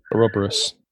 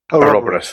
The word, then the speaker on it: ouroboros: Ouroboros. – Ouroboros, that was it.